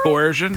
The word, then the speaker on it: coercion: Now, he posted on Instagram the definition of coercion. Am I saying that right? – Coercion? – Coercion. – Yeah. Coercion.